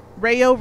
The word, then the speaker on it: Ryo: Let's talk about Mabu and Ryo. – Rayo. – Ario Speedwagon. – And that otter. – Rayo rhymes with gayo.